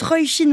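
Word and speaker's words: Róisín: D'ici là, on écoute Roy Shin Murphy avec Fader, euh, que j'ai appris à prononcer correctement grâce à une auditrice attentive.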